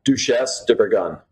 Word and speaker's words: Duchesse de Bourgogne: Duchesse de Bourgogne.